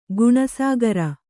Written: ಗುಣಸಾಗರ

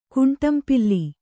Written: ಕುಂಟಂಪಿಲ್ಲಿ

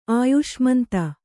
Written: ಅಯ್ಸಾಮಂತ

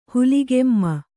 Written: ಹುಲಿಗೆಮ್ಮ

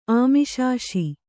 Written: ಆಮಿಷಾಶಿ

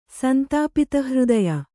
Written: ಸಂತಾಪಿತಹೃದಯ

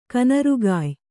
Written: ಕನರುಗಾಯ್

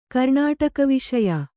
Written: ಕರ್ಣಾಟಕವಿಷಯ

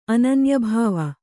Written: ಅನನ್ಯಭಾವ

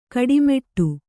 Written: ಗಟ್ಟಿಮುಟ್ಟು

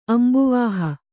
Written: ಅಂಬುವಾಹ

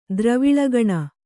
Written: ದ್ರವಿಳಗಣ

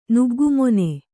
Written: ನುಗ್ಗುಮೊನೆ

